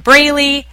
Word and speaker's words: Braley: The Jolynn, J-O capital L-Y-N-N, Braley, capital B-R-A-L-E-Y, show.